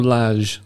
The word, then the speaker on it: Lage: Now, I know you are a big fan of Bruno Lage. That's how you say his name, isn't it? I believe so.